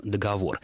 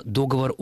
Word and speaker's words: договор: Поэтому, если там и было слово «договор», то оно было только с одним ударением «договор». Вот в современных словарях некоторых с определенной пометой «разговорная», «сниженная», «просторечная» или «с запретительной пометой», как было это еще несколько лет назад, форма «договор» указана, но она везде еще раз маркирована как недопустимая.